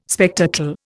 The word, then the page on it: spectacle: Indian